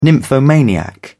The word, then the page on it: nymphomaniac: British English